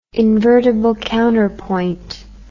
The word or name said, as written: invertible counterpoint